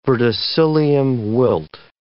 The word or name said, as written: verticillium wilt